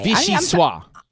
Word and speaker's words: Vichyssoise: Vichyssoise Day. What is Vichyssoise? – What day? What'd you say? – Today is National Vichyssoise Day. – Vichyssoise Day. – Vichyssoise.